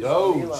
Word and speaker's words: Doge: Doge, say it right. I can't handle it. Doge. Doge. – Doge, like fucking...